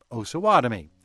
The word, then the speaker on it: Osawatomie: So the more correct pronunciation would be with the long O. So there you have it, though I'm sure we'll get more emails from Osawatomie.